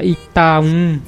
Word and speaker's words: Itaú: A pronúncia é bem diferente. É, se pronuncia Itaum.